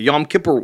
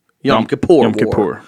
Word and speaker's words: Yom Kippur: They've been part of bigger wars, the Seven Day War, the Yom Kippur War, that involved multiple nations. – Yom Kippur. Please pronounce it right.